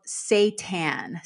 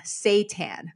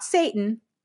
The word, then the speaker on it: seitan: The first one is called seitan. Seitan, not Satan, seitan. So that's spelled S-E-I-T-A-N.